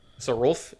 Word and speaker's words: Sarulf: To Sarulf. – Sarulf, yeah, Sarulf Realm Eater.